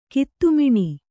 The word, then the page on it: ಕತ್ತಿಮೀನು: katti mīnu